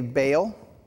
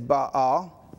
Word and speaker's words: Baal: We say Baal, but the correct pronunciation is Baal. There is an apostrophe that goes between the two A's. It's actually two syllables.